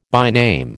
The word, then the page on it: byname: American English